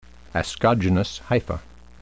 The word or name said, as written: ascogenous hypha